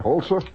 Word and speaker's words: Holzer: Did I pronounce it right? Holzer? Yeah, okay.